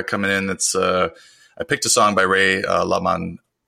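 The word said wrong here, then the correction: LaMontagne: How do you pronounce that? – La Montagne. We're kicking it off strong. La Montagne, I believe, is how you say it.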